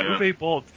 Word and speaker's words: Uwe Boll: Uwe Boll? Is that how you pronounce it? Yeah, Uwe Boll.